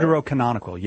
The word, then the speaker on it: Deuterocanonical: As you guys call them, Deuterocanonical – I think that's how you say it. – Deuterocanonical, yes.